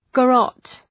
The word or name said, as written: garrotte